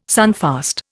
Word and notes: sunfast: Indian

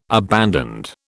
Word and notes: abandoned: British English

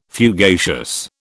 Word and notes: fugacious: British English

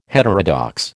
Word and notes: heterodox: American English

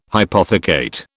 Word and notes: hypothecate: British English

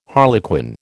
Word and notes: harlequin: American English

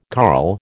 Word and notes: carl: American English

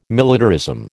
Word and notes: militarism: American English

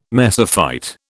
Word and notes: mesophyte: British English

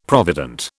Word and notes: provident: British English

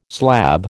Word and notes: slab: American English

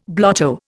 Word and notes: blotto: Indian

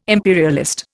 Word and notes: imperialist: Indian

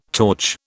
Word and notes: torch: British English

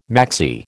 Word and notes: maxi: American English

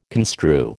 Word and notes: construe: American English

